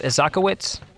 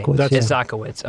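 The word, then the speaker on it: Isakowitz: I know there are some key players in this whole story, and I might butcher their names, so just correct me if I do. Steve Isaacowitz? – Yeah. – Okay. Isaacowitz. Okay.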